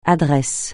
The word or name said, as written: adresse